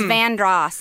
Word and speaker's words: Vanderhorst: Vandross Street is spelled V A N D E R. H O R S T, Vanderhorst. But it's Vandross.